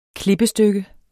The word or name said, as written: klippestykke